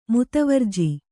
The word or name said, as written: ಮುತುವರ್ಜಿ